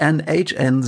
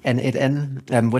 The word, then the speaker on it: n8n: N8N, ich muss immer aufpassen, dass ich nicht NHN sage. Wie wird es denn jetzt ausgesprochen und woher kommt denn der Name? – Ja, heißt wirklich N8N.